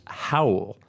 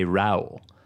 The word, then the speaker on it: Raul: He mentioned, when I was looking up how to pronounce it, he said that it's actually pronounced Howell, but people in America say Raul.